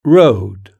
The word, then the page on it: road: British English